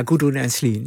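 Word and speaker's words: Gudrun Ensslin: Gudrun, zeg ik het goed? – Ja, Gudrun en Sleen.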